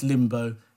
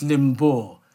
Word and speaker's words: Limbaugh: By the way, if anyone's wondering, it's not pronounced Limbo, it's pronounced Limbaugh.